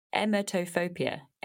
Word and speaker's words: emetophobia: emetophobia. How do I say it? – Emetophobia, I guess, in English. – Emetophobia. – Yeah, in French, it's emetophobie.